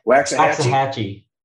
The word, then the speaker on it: Waxahachie: How does that pronounce? – Waxahachie. – Waxahachie. What the? It's a strong X. I would have never guessed that.